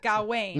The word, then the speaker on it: Gawain: And you put the emphasis on the first syllable. And so it's Gawain. Gawain. It doesn't rhyme with Dwayne.